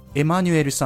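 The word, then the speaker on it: Emmanuelle: しかし、発音上は全く変わらず、エマニュエルさんを呼ぶときは、女性であっても男性であっても、エマニュエルと呼びます。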